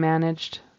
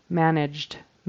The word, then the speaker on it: managed: And then the word managed, he pronounces that ED as a separate syllable. And in that particular word managed, the past tense verb, we don't pronounce the whole syllable. We just put a D on the end.